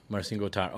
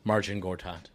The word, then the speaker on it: Marcin Gortat: Yep, Marcin Gortat. Although I said it that way because that's how it's pronounced. Marcin Gortat.